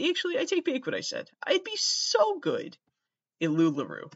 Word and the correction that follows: LuLaRoe: Am I saying it wrong? Probably. I think it's LuLaRoe.